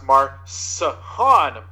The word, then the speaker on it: MarShon: Uh, I believe that's pronounced MarShon Brooks.